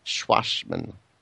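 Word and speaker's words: Schwassmann: It's not a schwack, it's a schwass. – I'm always getting told off for pronouncing that one by Michael. – S-C-H-W-A-S-S, Schwachmann.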